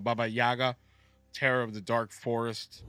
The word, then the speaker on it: Baba Yaga: Baba Yaga.